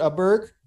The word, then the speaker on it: Åberg: But Ludvig Åberg, I believe is how you pronounce it, Åberg would be the correct way.